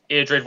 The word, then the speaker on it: Eadred: So poor old Edmund's just been killed, and he is replaced by his brother, Eadred. I don't know, is that how you say it, do you think? – I would say Eadred, yeah, I'd say Eadred.